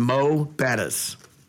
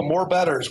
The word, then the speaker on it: Mo'Bettahs: So appreciate you guys saying it right, by the way. Let me start there. So many people love to start off and say, oh, more betters. We're going to go to more betters.